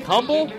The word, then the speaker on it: Humble: You spell that like humble? As if it's pronounced humble.